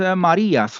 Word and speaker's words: Marías: و فامیلیش هم به صورت ماریاس خونده میشه که حالا من یکم راحتتر و فارسیتر و اینا تلفظش میکنم.